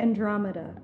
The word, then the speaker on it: Andromeda: Okay, anyway, so Andromeda... How do you say it? – Andromeda.